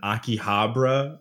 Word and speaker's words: Akihabara: Let me tell you about those ones in Akihabara or whatever.